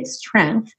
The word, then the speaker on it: strength: Nessa palavra, fica bem mais fácil de pronunciar quando você esquece o G, strength.